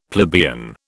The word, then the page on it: plebeian: British English